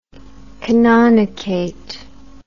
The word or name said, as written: canonicate